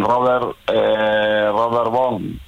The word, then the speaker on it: Robert Vaughn: Con Robert Vaughn, no sé si lo pronunció bien.